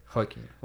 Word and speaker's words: Joaquin: Is that how you say it? Joaquin? – Joaquin. Well, because it's Spanish, right? – Oh, sorry.